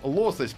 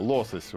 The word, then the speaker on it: лосось: Э, выяснили, ребята, только что, что лосось правильно говорить по-русски. Дело в том, что я занимаюсь э, ударениями и купил себе новые словари, и сейчас ребят просвещаю. Лосось.